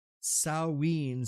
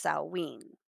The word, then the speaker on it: Samhain: the way it's pronounced, Samhain. So sow as in piggy.